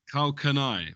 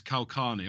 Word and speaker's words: Karl Kani: Carl Canai, right? But where I'm from, T, is Carl Carney. All right, mate. It's the Luton pronunciation.